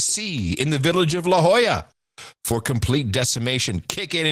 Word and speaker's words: La Jolla: Now, how would I pronounce this? Yahola Salt Corporation? J-O-L-L-A? La Jolla. La Jolla Salt Corporation. La Jolla.